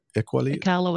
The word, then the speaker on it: Iqaluit: When a Swiss 777 diverted to Iqaluit.